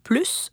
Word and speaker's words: plus: Avec les noms et les verbes dans les comparatifs et les superlatifs, le S du mot « plus » se prononce.